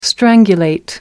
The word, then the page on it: strangulate: American English